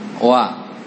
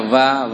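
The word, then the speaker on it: wa (و): Jangan dibaca va, wi, wu.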